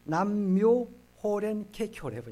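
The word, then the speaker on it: 남묘 호랭개교 (南無妙法蓮華経): 저희 어머니는 남녀 호랭개요. 남녀 호랭개가 뭐 옛날에 많이 아시잖아요. 남녀. 그러니까 그 남 이것이 우리는 남녀 호랭개인데 정확한 발음은 남묘 호랭개교를 해보니까 일본에서 이게 한마디로 수입된 것입니다.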